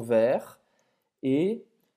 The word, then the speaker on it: verte: Então, vert, você não vai pronunciar o T, et verte, Et verte. Et agora você vai prononcer o T. Une feuille verte.